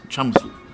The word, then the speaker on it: chamisul: So it's spelled C-H-A-M-I-S-U-L, and it's chamisul. – Yes, chamisul.